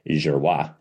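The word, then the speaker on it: Juruá: So this is Portuguese, and you know, I'm butchering the language, but it's J-U-R-U-A, Jiruá.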